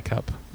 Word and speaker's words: Kupp: Yeah, I-C-U-P-P. – Yeah, I-C-U-P. – I-C-U-P-P. – But his name is K-U-P-P. – I-K-U-P-P? – And it's pronounced Cup. – I-K-U-P-P? – Yeah.